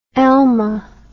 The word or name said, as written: elma